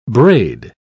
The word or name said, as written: braid